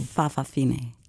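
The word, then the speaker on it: fa'afafine: Fa-fa-fine. – And it's spelled F-A-apostrophe-A-F-A-F-I-N-E, if Correct.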